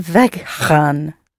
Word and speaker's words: weggaan: Ik wil weggaan. – Ik wil weggaan. – Weggaan. – That's it. Ik wil weggaan. – Is it spelled with two G's? – Yes, two G's. W-E-G-G-A-A-N. Weggaan.